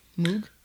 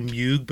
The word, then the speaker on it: Moog: Moog? – I believe, I think it depends on how you pronounce the guy's name. I've heard a lot of people say Moog, but I think it's actually Moog.